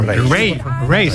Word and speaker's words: Raye: Ray.